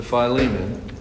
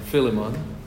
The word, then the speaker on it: Philemon: If you could take your copy of God's Word and turn with me this evening to Philemon or Philemon, both pronunciations are acceptable.